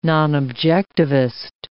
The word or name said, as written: nonobjectivist